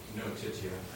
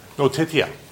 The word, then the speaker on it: notitia: Is that the correct Latin pronunciation on that? Notia? – Notitia. – Notitia.